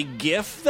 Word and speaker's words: GIF: The G is from graphical, that it should be pronounced as a G. So I think that solves the whole problem. – Thanks. Yeah, so if the G in GIF stands for graphics, shouldn't it be GIF then?